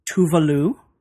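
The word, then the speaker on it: Tove Lo: kept calling her Tovlo, Tovlo. – Right, Tavlo. – Tavlo. – Tavelo. – I found out that her name is actually pronounced Tuvalu.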